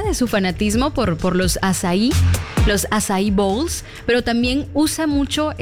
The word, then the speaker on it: açaí: Yo recuerdo la primera vez que vi açaí en algún menú. Recuerdo haber dicho, ay, ¿me puede traer un acai? No sabía la pronunciación.